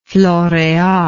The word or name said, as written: floreal